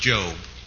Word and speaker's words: Job: Well, why should he know that's pronounced Job? Spelled Job.